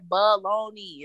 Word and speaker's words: bologna: Think about it. It's like baloney. It's not like balani. It's baloney.